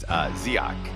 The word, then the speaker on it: Dziak: It gets to be whatever it is to you, but it's Dziak.